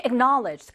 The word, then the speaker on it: acknowledge: Knowledge라는 단어에다가 AC를 붙인 거라고 생각하시면 acknowledge 이렇게 발음이 됩니다.